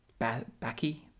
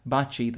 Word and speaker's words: Baci: B-A-C-I. It looks like Bacchi, I don't know how you pronounce it in English, but it's pronounced Baci in Italian, mm-hmm.